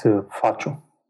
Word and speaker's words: Fâciu: It's Fâciu.